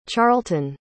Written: charleton

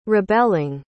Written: rebelling

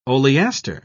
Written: oleaster

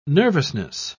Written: nervousness

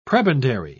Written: prebendary

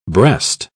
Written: breast

